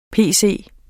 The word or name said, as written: pc